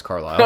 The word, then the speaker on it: Carlisle: Carlisle. Is that how you say it? – No, it's Carlisle. – I was going to say, wait, what? – I don't know why I put that weird emphasis.